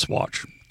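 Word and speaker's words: Saguache: That's even a shortened Ute word for like blue water or something like that, but it's pronounced Swatch. Don't ask me.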